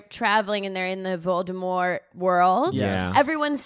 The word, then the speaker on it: Voldemort: Rowling abides by this, it's pronounced Voldemort, silent T.